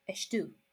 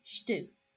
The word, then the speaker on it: estou: So there's, I can't think of a number example, but in order to say I am, you say estu. Only you don't say estu, you say stu. You just, the E sound at the beginning is almost, is kind of completely lost because it's unstressed.